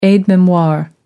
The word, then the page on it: aide memoire: American English